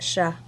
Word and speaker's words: chats: You don't pronounce that T? – We're still in France, so it's Shah. Shah, sorry. Shah. Le Shah, Le Shah, Des Shah. And that's spelled C-H-A-T-S.